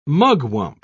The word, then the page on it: mugwump: British English